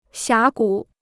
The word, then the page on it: 峡谷: xiá gǔ